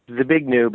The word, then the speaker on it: Zbigniew: Zbigniew, but they call him Zbig for short, like Z-B-I-G, Z like zoo, B-I-G.